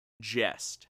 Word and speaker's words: gest: but it's spelled G-E-S-T, and it's pronounced jest. – G-E-S-T. – Jest. Not like he did that in jest or whatever, but it's jest.